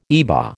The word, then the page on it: IBA: American English